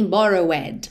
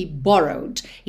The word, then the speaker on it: borrowed: Primeiro, você reparou que esse é da terminação ed que a gente acrescentou, não é pronunciado. Eu não falo begged e nem borrowed, ok? Eu falo begged e borrowed.